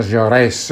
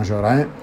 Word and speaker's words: Jaurès: in particolare a partire dalla figura di Jean Jaurès, si pronuncia Jaurès e non Jaurès, Jaurès, che scrive l'Histoire socialiste de la Révolution française, che esce ai fascicoli tra il 1901 e il 1904.